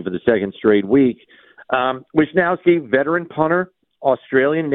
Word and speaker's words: Wishnowsky: Good job there. Wishnowsky, right. That's how you say it.